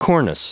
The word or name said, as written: cornice